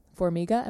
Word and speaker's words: Formiga: Formiga, am I saying that correctly? – Mm-hmm.